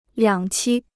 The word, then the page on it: 两栖: liǎng qī